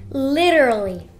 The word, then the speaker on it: literally: Então é uma palavra que é meio tricky, né? Meio que difícil para nós pronunciarmos, porque ele parece que quer enroscar a língua, né? Literally, né? Se você tiver uma pronúncia mais britânica, você pode, né, fazer aquele som do T, tipo literally, literally.